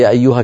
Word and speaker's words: يا أيها: يا أيها كيف كتبت، يا مكتوبة يا أيها، فلذلك علماؤنا زادوا ألفا خنجرية حتى ينبه القارئ بأن هنا ألفا لا بد من نطقها، وإن هي لم تكن موجودة في الخط، لأن العرب كما أسلفت مرات وكرات لا تجمع في الخط بين ألفين اختصارا، اختصارا.